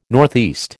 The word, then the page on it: NE: American English